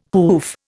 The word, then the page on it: hoof: Indian